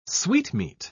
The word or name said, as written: sweetmeat